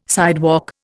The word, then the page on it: sidewalk: Indian